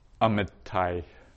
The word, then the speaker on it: Amitai: Amitai, dai, ai. So you say there at the end, that's that diphthong sound, ai.